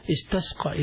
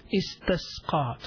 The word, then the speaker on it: استسقى: استسقى is alif seen, ta seen, qaf and ya maqsura, which is pronounced as an alif. استسقى, try to pronounce it as I'm saying it.